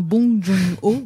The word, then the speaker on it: Bong Joon-ho: Alors, les films en compétition, donc on en parlait à l'instant avec Bang Joon euh, je, ne sais pas si tu connais la vraie prononciation d'ailleurs, un hein, Bong Joon-ho.